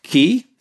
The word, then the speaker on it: Key: And then in Roscommon, Loch Key, it always sounds funny, that one, Loch Key, as in lock your front door, put the key in your pocket. Key is actually pronounced K in Irish, and it takes its name from K, who was a druid.